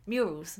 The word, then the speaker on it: murals: Yeah, so the cathedral has some fantastic Muriel. I kept saying this wrong. – Muriel. Muriel is a person. Murals.